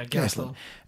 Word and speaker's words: Gastelum: Yeah, Gastelum.